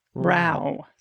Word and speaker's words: row: A listener or viewer wrote, Dude, row is a British term. It rhymes with how, not snow. It's a short O. I've heard it mispronounced several times. It's making my ears burn. So it's row.